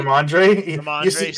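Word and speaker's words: Rhamondre: Robin Stevenson. What a name. – Did I say it wrong? – Ramondre? – Ramondre Stevenson. You said it very wrong. There's no B in his first name.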